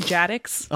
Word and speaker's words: GATX: Yeah, Gattix. – You know. – You know. – It's pronounced Jadix. – Oh, Jadix. No, true.